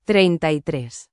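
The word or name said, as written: treinta y tres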